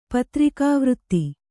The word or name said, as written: ಪತ್ರಿಕಾವೃತ್ತಿ